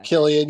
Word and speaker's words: Cillian: I've only ever read Cillian.